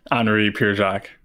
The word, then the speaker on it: Henri Pierre Jacques: Henri Pierre Jacques. – Okay, so you don't really lean into the French pronunciation as much as I've been wanting no, to.